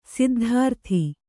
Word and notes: ಸಿದ್ಧಾರ್ಥಿ: siddhārthi

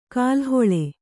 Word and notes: ಕಾಲುಹೊಳೆ: Kannada

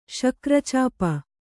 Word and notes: ಶಕ್ರಚಾಪ: śakra cāpa

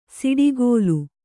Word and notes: ಸಿಡುಕಲು: siḍukalu

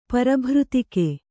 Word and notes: ಪರಭೃತಿಕೆ: kannada

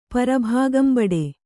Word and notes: ಪರಭಾಗಂಬಡೆ: kannada

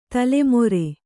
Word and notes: ತಲೆಮೆರೆ: tale mere

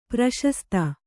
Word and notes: ಪ್ರಶಸ್ತಿ: kannada

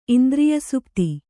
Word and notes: ಇಂದ್ರಿಯಸುಪ್ತಿ: indriyasupti

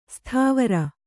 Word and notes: ಸತ್ವರ: satvara

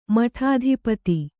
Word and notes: ಮಠಾಧಿಪತಿ: kannada

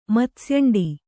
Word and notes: ಮತ್ಸ್ಯಂಡಿ: kannada

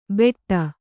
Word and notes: ಬೆಟ್ಟ: kannada

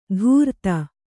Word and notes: ಧೂರ್ತ: kannada

